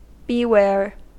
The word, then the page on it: beware: American English